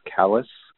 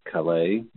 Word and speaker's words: Calais: So I will take on Calais, and of course it is Calais, Calais, not Calais, or some other French pronunciation. And I know because I live here.